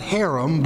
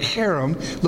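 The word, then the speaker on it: cherem (חרם): Not harem, but cherem. Looks like this. C-H-E-R-E-M. Okay, so this is the letter H here, there. H, and then we have a short E, and then we have an R, another short E, and then M.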